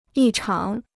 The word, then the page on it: 异常: yì cháng